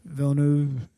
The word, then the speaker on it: Villeneuve: A lot of people are complaining that Denis, I don't know how to pronounce Villeneuve.